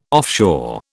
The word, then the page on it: offshore: British English